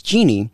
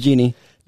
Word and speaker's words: Jeanne: And also my grandma's name, my mom's mom, her name is Jeannie, but it's spelled like J-E-A-N-N-E. – Oh. – So. – Yeah. – So her name is Jeannie? – Her name is Jeannie.